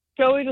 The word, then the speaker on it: Chloe: Nu skal vi have, jeg tror det udtales Chloe, eller Chloe? – Ja, Chloe, du sagde det rigtigt først.